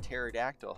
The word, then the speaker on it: pterodactyl: So like P is for pterodactyl, but pterodactyl has a silent P at the beginning.